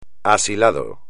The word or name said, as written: asilado